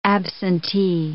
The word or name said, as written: absentee